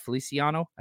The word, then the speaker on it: Feliciano: His name right, Feliciano.